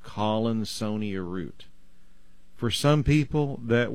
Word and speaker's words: Collinsonia: It's C-O-L-L-I-N, and then S-O-N-I-A, almost like the word Sonia. Collinsonia. – Okay. – At least that's the way I pronounce it.